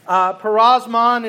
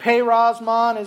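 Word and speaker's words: peirasmos: Parosmon is the Greek. Perosmon is actually the way to pronounce it.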